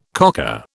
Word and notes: cocker: British English

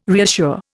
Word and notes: reassure: Indian

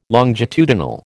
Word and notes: longitudinal: American English

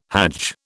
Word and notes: hajj: British English

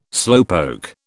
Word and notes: slowpoke: British English

